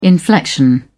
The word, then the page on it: inflection: British English